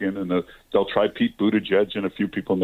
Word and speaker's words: Buttigieg: I love the way Trump would explain to people how to pronounce Buttigieg. It's Buttigieg. Edge, edge. You got to say edge, edge.